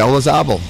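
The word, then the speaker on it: Olazábal: Jose Maria Olazabal. – Yeah, Olazabal, yep.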